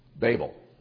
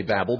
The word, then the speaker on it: Babel: This place was called, we refer to it as Babel. Some people say Babel, but that would be two B's. Okay? It was called Babel.